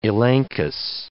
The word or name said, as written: elenchus